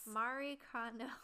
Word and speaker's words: Marie Kondo: Marie Kondo. – Exactly. Yes, Marie Kondo. – Is that how you say her name? I'm sorry. I watched the video once. – Marie Kondo.